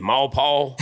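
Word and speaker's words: Maurepas: We've been to, how they say it, Maripaw, yeah.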